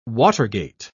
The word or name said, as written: watergate